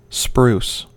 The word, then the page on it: spruce: American English